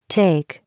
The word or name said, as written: take